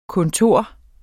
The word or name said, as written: kontor